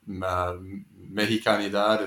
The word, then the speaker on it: Mexicanidad: So I wonder if you can talk a little bit about, you know, Jose Nápoles and Mexicanidad. Is that how you pronounce that? – Yes. – Word? – Yeah, it's a very good pronunciation.